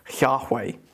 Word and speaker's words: Yahweh: So Yahweh would have been actually spelt out in translation YHWH, which we believe is pronounced, most scholars believe is pronounced Yahweh.